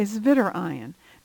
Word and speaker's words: zwitterion: The W is pronounced as a V, a zwitterion.